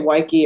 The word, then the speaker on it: Weike: I usually say Weike, it rhymes with Nike, or it's like Beach.